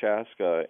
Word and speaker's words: Chaske: But one of the most poignant tragedies is that Chaska simply means, in Dakota, the word Chaska, and I'm pronouncing it in an anglicized way, it's Chaske, it's closer to the Dakota pronunciation, but Chaska means firstborn if a male.